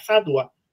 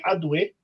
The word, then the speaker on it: Hadwa: Pero si ustedes ponen la palabra Hadwa, pero con E final, no como se pronuncia aquí, Hadwe, ¿ah?